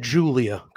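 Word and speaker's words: Giulia: you know, it's just, and Kristen's saying now, they pronounce that Julia, correct? – Exactly. Yes. Okay.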